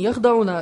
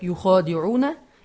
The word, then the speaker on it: يُخَادِعُونَ: То есть в оригинале Джалалейна пишется, сейчас поясним, не «яхдауна», а читается как «юхадиуна إِلَّا أَنفُسَهُمْ».